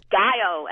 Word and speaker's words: Gallo: El Gallo, many of you write. El Gallo is how the character pronounced his name.